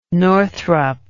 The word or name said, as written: northrup